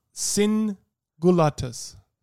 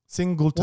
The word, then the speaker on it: Singultus: My Latin name is Singulatus. Singulatus. – Singulatus. – Yeah. Huh. I mightn't be pronouncing that 100% correct in Latin. Singulatus. – Gulatus. – Gultus. Singultus.